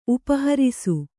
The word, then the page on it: ಉಪಹರಿಸು: Kannada